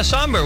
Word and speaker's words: sombr: Somber, S-O-M-B-R. – Oh, yeah, Somber.